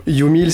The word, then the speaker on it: U Meal: Humil, alors j'ai mal prononcé, voilà, ça commençait pas bien. – Oui, vous auriez dû me le dire. – Humil, c'est you en anglais, donc toi.